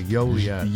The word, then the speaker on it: Joliet: That's a soft J. Joliet.